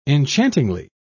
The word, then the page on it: enchantingly: British English